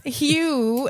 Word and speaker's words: Hxe: So this artist named Hugh, and of course, here y'all go throwing X's and shit. It's spelled H-X-E.